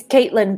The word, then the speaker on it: Caitlin: Yes, it's Caitlin, but I've lived all over the world for such a long time that I'm really accustomed to.